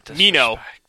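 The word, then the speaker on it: Minho: Okay, Mino. Did I say it right? – Yeah.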